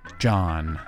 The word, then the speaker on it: Dgohn: Let's get lost in the music of the artist John, and that is spelled rather uniquely as D-G-O-H-N, John.